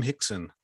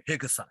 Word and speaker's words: Rickson: And for that time period, shortly, he was, people knew that name Hickson. – Yeah, spelled Rickson, but in Portugal, you have to, you know, pronounce it like Hickson.